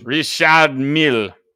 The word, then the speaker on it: Richard Mille: Richard Mille.